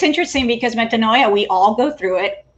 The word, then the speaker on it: metanoia: metanoia.